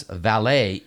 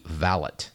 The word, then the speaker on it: valet: And finally, it's not a French word, so pronouncing the last syllable of valet as valet is incorrect. It should be valet.